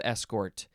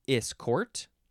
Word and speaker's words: escort: Now, this one, you can pronounce this escort, escort, or escort. You can emphasize either syllable, and the first syllable is is or s.